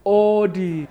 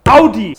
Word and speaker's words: Audi: So, the first wrong car name, so many people say Audi, when it's actually pronounced Audi.